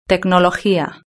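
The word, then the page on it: tecnologia: Spanish (Spain)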